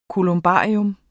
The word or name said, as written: kolumbarium